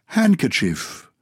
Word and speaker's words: handkerchief: It's an interesting one because handkerchief, hanky is H-A-N-K-I-E. – Yes. – But handkerchief. – Kerchief, your hand. – Yeah. But you don't pronounce the D, so it's a silent D.